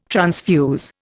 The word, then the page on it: transfuse: Indian